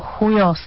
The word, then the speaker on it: Chujos: Zvláštní název Duo Chujos se čte španělsky Duo Chujos. Já jenom, abyste pochopili, proč se mluví o té výslovnosti, ono se to píše duo Chujos, jo, čili španělský Chujos.